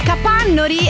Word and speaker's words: Capannori: Invece volevo salutare gli amici di Capannori, ecco, questa è la pronuncia giusta, volevo salutarli così.